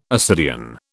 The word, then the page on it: ascidian: British English